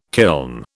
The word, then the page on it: kiln: British English